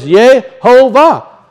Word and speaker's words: Yehovah: When you look at it up again in Strong's, its pronunciation is Yehovah. That's what it says.